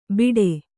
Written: ಬಿಟೆ

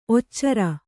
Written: ಒಚ್ಚರ

